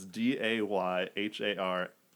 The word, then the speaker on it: Descharnais: His last name is not spelled even close to correctly, but I spelt it phonetically. It's DeHarnay.